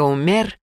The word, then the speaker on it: Умер: Дядюшка Умер, ударение непременно делается на второй слог, уверен в этом и потому вовсе не печется о стерильности разлетающихся, как горячие пирожки, уличных завтраков.